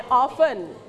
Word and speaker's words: often: โอเคนักเรียนระวังคำนี้นะคะคำว่า often มันเขียนว่ามันใช้ตัว t แต่มันไม่ได้ออกเสียงตัว t นะคะเป็น often not often